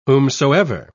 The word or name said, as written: whomsoever